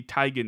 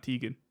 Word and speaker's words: Teigen: And it's Christy Teigen, not Teigen.